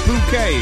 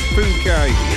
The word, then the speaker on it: Phuket: And let's not read it out the way it's spelt. We've not long started, have we? So, yeah, I think it's Phuket.